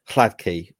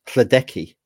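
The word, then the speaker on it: Hladký: Hladky was pronounced as Hladeki which I uh, which I quite enjoyed.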